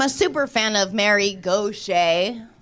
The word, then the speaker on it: Gauthier: It looks like Mary Gauthier or something like that, but it's pronounced Gaucher. Because I think it's like, ugh, Cajun or something.